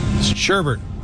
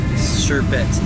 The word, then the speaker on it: sherbet: Sherbert. – I would pronounce it sherbert. – Correct. – Why did you say? – Because there's no R at the end, like sherbet, not sherbert.